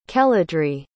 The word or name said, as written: keladry